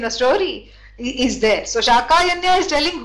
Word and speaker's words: Śākāyanya: No, wrong. Shakayanya, correct. See, this is so much fun.